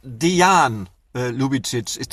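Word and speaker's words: Dejan Ljubičić: Übrigens, Frank, nur zur Korrektur, Dejan Lubicic. Das habe ich gelernt.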